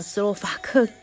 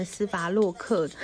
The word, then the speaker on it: 斯洛伐克: 呃，你超过三十一岁，就只剩下加拿大、斯洛伐克和匈牙利可以选择。那那时候我们都知道，斯洛伐克真的是一个很陌生的国家，我甚至都还会念错它的名字，念成斯伐洛克，然后也不知道它确切的位置。